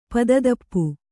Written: ಪದಿತೂಪು

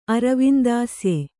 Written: ಅರವಿಂದಾಸ್ಯೆ

